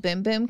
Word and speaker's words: Bum Bum: And they told me it's called Boom Boom Cream, not Bum Bum. Bum. And it blew my mind. They're like, yeah, boom, it's actually boom. Pronounced Boom Boom Cream.